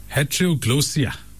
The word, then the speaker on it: heteroglossia: Heteroglossia. That's how you pronounce it.